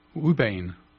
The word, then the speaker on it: ouabain: It's spelled as O-U-A-B-A-I-N. – Ubain. – Yeah.